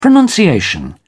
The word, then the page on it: pronunciation: American English